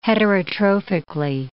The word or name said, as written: heterotrophically